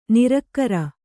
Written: ನಿರಾಕಾರ

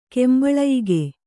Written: ಕೆಂಬಳಯಿಗೆ

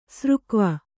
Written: ಸರಗೋವಿ

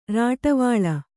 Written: ರಾಟವಾಳೆ

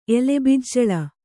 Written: ಎಲೆಬಿಜ್ಜಳ

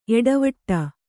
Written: ಏಟವೀಟ

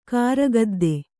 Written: ಕಾರುಗದ್ದೆ

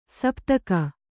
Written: ಸಪ್ತಕ

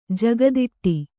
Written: ಜಗದಿಟ್ಟಿ

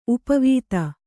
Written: ಉಪವಿಧಿ

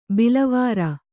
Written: ಬಿಲವರ

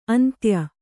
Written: ಅಂತ್ಯ

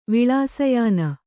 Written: ವಿಳಾಸಯಾನ

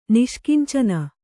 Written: ನಿಷ್ಕಿಂಚನ

